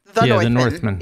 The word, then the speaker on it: The Northman: The Northman.